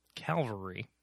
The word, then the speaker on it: Calvary: Which again was disappointing because you and I are both huge films, huge fans of John Michael McDonough's film Calvary, which is really hard to say without saying Calvary. – Well, you said it right the second time. – Oh, crap. Cavalry versus Calvary. – Cavalry are mounted horseback soldiers. Calvary is the hill on which Christ died.